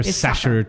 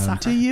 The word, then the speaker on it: Sacher: Is it Sacher? You hear Sacher tort a lot. – No, it's Sacher.